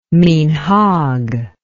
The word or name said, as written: minhag